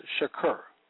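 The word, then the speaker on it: shakur (שֶׁקֶר): Hebrew word for lying is shakur, pronounced shakur, meaning untruth, a sham, without a cause, deceit, deceitful, falsehood, liar, lie, vain, wrongfully.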